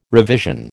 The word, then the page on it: revision: American English